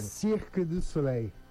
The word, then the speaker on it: Cirque du Soleil: Por favor, qual que é a pronúncia correta em francês, você que esteve lá durante muito tempo? Fala pertinho do microfone. – Cirque du Soleil.